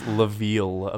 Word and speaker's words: Lviv: It's pronounced Lviv, apparently.